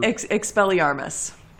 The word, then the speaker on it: Expelliarmus: How do you pronounce that spell? Like Expelliar- Expelliarmus. Expelliarmus. And we're going to see it okay.